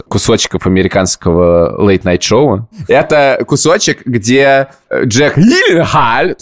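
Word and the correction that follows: Gyllenhaal: то есть Гилленхолл, приходит на шоу, и они обсуждают, как на самом деле... Произносится его фамилия. Гилленхолл.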